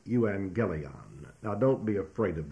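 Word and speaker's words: euangelion (εὐαγγέλιον): And to state it simply in the Greek language, when two G's come together, one takes the N sound, and therefore this word, euangelion, would be pronounced euangelion. Euangelion. And you will notice in there that there is in this the word angel, A-N-G-E-L.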